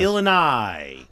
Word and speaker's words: Illinois: I didn't pronounce the S. – Yes. – Illinois.